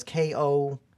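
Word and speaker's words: Kosupure (コスプレ): I'm definitely not doing my good Japanese here, but it's like kazu. Oh wait, that's it. Kazupor. Like poor. Like, it's really hard to pronounce. So it's Kosupure.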